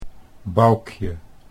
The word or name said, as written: Boukje